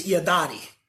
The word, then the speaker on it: yadati: The Hebrew is yadati. That's how you say it.